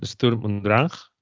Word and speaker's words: Sturm und Drang: Sturm und Drang.